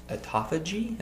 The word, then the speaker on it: autophagy: I know on the first part of the water fasting, you said autophagy.